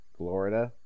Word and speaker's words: Glorida: R-E-O-R-X is pronounced Reorks. X-A-N-D-A-R is pronounced Xandar. T-A-R-A-N is pronounced Taran. And G-L-O-R-I-D-A is pronounced Glorida. Maybe that's Glorida. Rhymes with Florida.